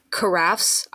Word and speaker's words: carafe: Water carafes. I looked up how to say it because people keep arguing with me. It's carafe.